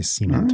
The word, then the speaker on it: cement: Oh, you put the wrong emphasis on the wrong syllable. – No, yeah, it opens the door for semen jokes. Is what's happening there? – It does not. Does not. I always say cement.